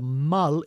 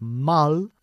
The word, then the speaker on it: mull: Now, an important point about this phrasal verb is we pronounce it mull and not mull. So okay. The U is an English U, not a Spanish U.